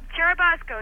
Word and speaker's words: Churubusco: Tell us, how do you pronounce that? Churubusco? – Churubusco, yes.